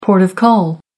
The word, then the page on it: port of call: American English